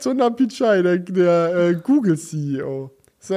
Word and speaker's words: Sundar Pichai: Es war dann Sunda Peach. Äh, fuck, wie, wie, spricht man ihn aus? Sundar Pichai, oder?